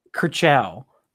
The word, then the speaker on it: Kershaw: Is that how you say it? Is it Kershaw? – Kershaw. Like in cars.